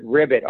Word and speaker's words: Ribet: But Rye Bay Academy, or some people pronounce it Ribbit, R-I-B-E-T.